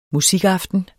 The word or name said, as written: musikaften